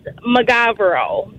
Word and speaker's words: Mogavero: So our last name is McGavero. – McGavero. – It's spelled M-O-G-A-V-E-R-O. – What did they say? – A lot of people pronounce it Mogavero, which is fine.